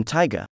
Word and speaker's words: Taiga: He is among 21 players on the Japan Golf Tour with the name Taiga. It doesn't translate to tiger, but it is pronounced that way, and it's no coincidence.